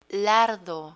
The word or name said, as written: lardo